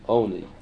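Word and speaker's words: oni (עני): Lechem oni is written with an ayin, oni. But we darshan it as if it were written with an aleph.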